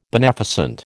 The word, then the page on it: beneficent: American English